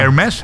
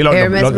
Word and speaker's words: Hermès: Hermès. – Sí, Hermès, mira, Birkin Bags.